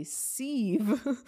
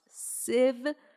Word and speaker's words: sieve: Thank you to those of you who DM'd me the last time I said that word and I pronounced it a sieve and told me that I was pronouncing it wrong. It's pronounced sieve like Shiv from Succession.